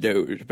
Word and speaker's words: Doge: So, U/Moland, M-O-H-L-A-N-D. You are behind Dogecoin. – It's pronounced Doge, but yeah.